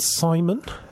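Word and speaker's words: CIMON: And it's called Simon. That's Crew... Interactive Mobile Companion. Simon's in there somewhere, but it's a soft C at the start. And Simon with a C.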